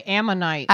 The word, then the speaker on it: Ammonite: Or is it amenity? – How would you say that word? – I would say ammonite. – I would say ammonite. – It's ammonite.